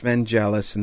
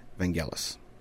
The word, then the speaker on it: Vangelis: Now, before I get a huge amount of email saying it's pronounced Vangelis and that's all, you know, no, I have it on very, very, very good authority that it is pronounced, in fact, Vangelis.